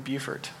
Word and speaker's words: Beaufort: My wife and I, we got married in June of 2008 down in Beaufort, South Carolina. So I pronounced that correctly, Beaufort, South Carolina.